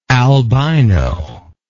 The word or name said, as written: albiroid